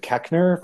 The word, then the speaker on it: Koechner: played by David Koechner, right? That's how you pronounce his name.